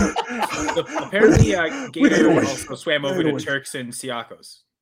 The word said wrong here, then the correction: Caicos: Oh, nice, Caicos. Gator. – I don't know how to pronounce that. Is that Caicos? Caicos. I don't know how to pronounce it. – Turks and Caicos.